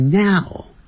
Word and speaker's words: now: 句尾的这个 now N-O-W 念是 now， now 先念啊，那个双元音啊，再念个 ow ow，不要念成 ow， ow 住嘴巴就没有全开，你会念成 now， now 就不对了，先念 na na 的 na， now now 在嘴巴缩小 now。